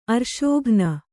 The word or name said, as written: ಅರ್ಶೋಘ್ನ